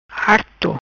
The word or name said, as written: Arto